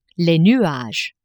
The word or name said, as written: nuages